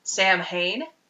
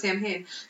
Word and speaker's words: Samhain: You might see it written and think it's Samhain, because it's spelled S-A-M-H-A-I-N, but it's not. It is Samhain.